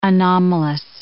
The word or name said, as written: anomalous